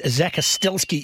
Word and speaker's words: Zakostelsky: You mentioned our guest, Zane Zakostelski, is in the studio. Is that, have I got that right? – Yeah, got that. – Is exactly right.